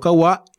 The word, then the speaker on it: Kwa: Entonces los Kawa, vamos a decirle Kawa, pero se escribe KWA, se expandieron eh, durante miles de años.